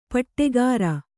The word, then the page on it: ಪಟ್ಟೆಗಾರ: paṭṭegāra; Kannada